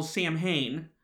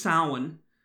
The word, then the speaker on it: Samhain: So we're going to start with Samhain, which many people mistakenly call Samhain, but the pronunciation is Samhain, and it's one of the most important holiday celebrations.